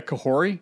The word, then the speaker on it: Kahori: Am I saying that right? – I believe it's, yeah, Kahori. Yeah.